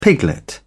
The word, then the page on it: piglet: British English